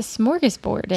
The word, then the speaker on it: smorgasbord: Is that how you say that word? A handful of tips? – No. – How do you say that word? – It's a smorgasbord, isn't it? – Smorgasbord. – Not Borg. – Borg. No. Smorgasbord.